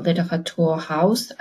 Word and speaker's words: Literaturhaus: in the Literaturhaus. I believe that's the pronunciation in German. Um, You're correct.